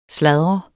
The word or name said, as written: sladre